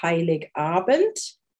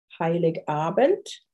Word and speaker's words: Heiligabend: Christmas Eve is Heiligabend. Heiligabend.